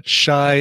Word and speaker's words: Chi: Well, no, it's pronounced Shy, by the way.